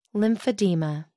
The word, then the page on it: lymphedema: American English